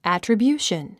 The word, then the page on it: attribution: æ`trəbjúːʃən; アトラビューション